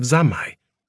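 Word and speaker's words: zammai (三昧): He said that that was good, and that I was beginning to experience the very first stages of zammai, which is the Japanese pronunciation of samadhi.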